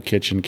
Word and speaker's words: Kitchin: Facebook, I'm Daniel Kitchen, KITCHIN.